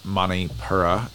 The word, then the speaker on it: Manipura: In fact, the next vibration up, the next chakra is the solar plexus, or Manipuri, or Manipura, excuse me, and that's probably still pronounced wrong, so shoot me,